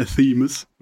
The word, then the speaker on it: Athemous: Meine Band heißt Athemus. Mhm. Das schreibt man wie? A-T-H-E-M-O-U-S.